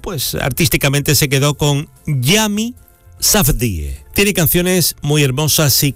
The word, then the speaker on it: Yami Safdie: Ah, y no te compliques con el nombre. Se pronuncia tal y como se escribe, Yami Safdie.